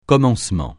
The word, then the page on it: commencement: francais